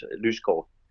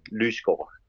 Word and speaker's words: Lysgaard: Yes, Lyskov, J-E-S, and L-Y, S-G-A-A-R-D, Lyskov, is it pronounced in Danish?